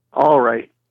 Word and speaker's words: awaruite: Awaruite, is that how you pronounce that? – All right. Is how it's pronounced.